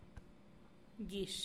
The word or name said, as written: gixh